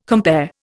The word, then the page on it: compare: Indian